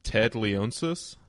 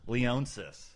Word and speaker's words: Leonsis: Ted Leonsis? – Leonsis.